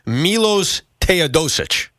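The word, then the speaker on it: Miloš Teodosić: and correct me if I got the pronunciation wrong, Milos Teodosic. Am I close on that? – Yeah, that's close.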